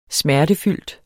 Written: smertefyldt

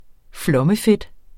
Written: flommefedt

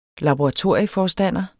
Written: laboratorieforstander

